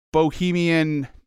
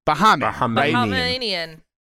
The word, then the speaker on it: Bahamian: Now, I will say, to spoil the next segment just a little bit, that this is a Bohemian, I'm such an idiot. This is a from the Bahamas. How would you say that? – Bahamian.